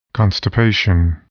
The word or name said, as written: constipation